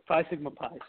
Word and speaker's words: Phi Sigma Pi: Phi Sigma Pi.